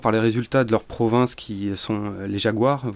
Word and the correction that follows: Jaguares: vous m'excuserez pour la prononciation. Euh, Jaguares. Qui, euh, voilà, les Jaguares, qui, euh, ils ont des résultats en hausse, ils, ils commencent à battre les Néo-Zélandais en Nouvelle-Zélande.